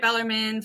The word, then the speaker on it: Bellarmine: It's pronounced Louisville, and it's pronounced Bellarmine. So I have been at Bellarmine for 11 years in a variety of roles, but I found my passion and my purpose when we got Slate.